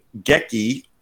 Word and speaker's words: Geki: So Retsu and Geki are computer-controlled opponents in which video game? – What were their names again?